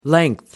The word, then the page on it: length: American English